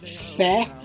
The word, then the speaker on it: fé: The word faith in Portuguese is fé, so it really rhymes with Candomblé.